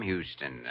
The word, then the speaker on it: Houston: But Houston's a famous Texas name, Sam Houston. – Sure, but it's also a famous Lower East Side name, the street where I was born. Except here in New York, you pronounce it Houston.